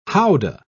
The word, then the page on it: houdah: British English